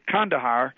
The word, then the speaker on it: Kandahar: And it was down in what is today Kandahar, which is the way local people now pronounce that name, and that's how it's evolved over the centuries.